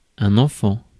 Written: enfant

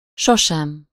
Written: never

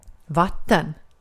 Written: vatten

